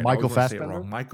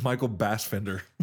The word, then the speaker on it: Fassbender: Michael Fassbender? – I always want to say Michael Bassfender.